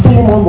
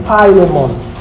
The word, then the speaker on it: Philemon: Well, our soul is not pronounced Philemon, but Philemon.